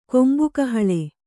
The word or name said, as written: ಕೊಂಬುಗಹಳೆ